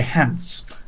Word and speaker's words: Hans: And so he has said his name is the Reverend Dr. Stephen Hans, or as you would say, Hans. and he has said to rhyme with pants.